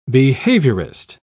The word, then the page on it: behaviourist: British English